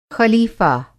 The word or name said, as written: ख़लीफ़ा